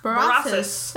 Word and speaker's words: Berossus: So I'm going to mispronounce this. Daniel mentioned him. It was Berosus. Berossus. – That sounds right.